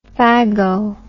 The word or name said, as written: phago